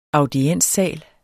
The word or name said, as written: audienssal